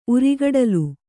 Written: ಉರಿಗಡಲು

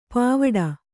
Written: ಪವಡಿ

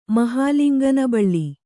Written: ಮಹಾಲಿಂಗನಬಳ್ಳಿ

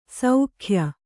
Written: ಶಾಕ್ಯ

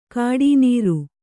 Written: ಗುಟ್ಟುನೆರೆ

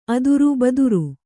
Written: ಅದುರು

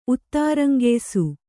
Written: ಉತ್ತಾರಂಗೆಯ್ಸು

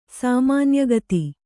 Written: ಸಾಮಾನ್ಯಗತಿ

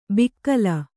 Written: ಬಿಕ್ಕಲ

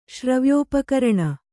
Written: ಶ್ರವ್ಯೋಪಕರಣ

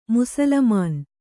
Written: ಮುಸುಲುಮಾನ

